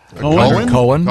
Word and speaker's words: Cohen: Cohen. Yeah, you. – This that's confusing because not when it's spelled C-O-H-E-N. – Definitely Cohen.